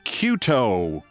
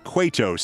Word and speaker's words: Cueto: saluting Chicago White Sox pitcher Johnny Cueto. Mispronounced his name like that 3 times in 30 seconds. Cueto. It's Cueto. C-U-E-T-O. He has been a Major League pitcher since 2008. It's always been Cueto.